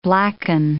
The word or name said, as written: blacken